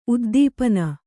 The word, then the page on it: ಉದ್ದೀಪನ: kannada